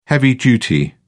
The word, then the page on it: heavy-duty: British English